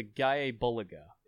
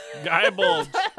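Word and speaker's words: Gáe Bulg: Also, I think the proper pronunciation, right. I was like doing some looking. I think it's the Gaia Bulga is the name of his spear. – That still Gaia sounds Bulga. Like Gaia Bulge. – Gaia Bulge. – It's the Gaia Bulge. – He's not wrong. The Gaia Bulge is a spear. – Gaia Bulga. – That's how it's pronounced.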